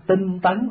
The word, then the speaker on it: tinh tấn: Chữ tiến có khi đọc là tấn, không? Tinh tấn cũng được, là tinh tiến cũng được.